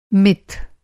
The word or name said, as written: मिथ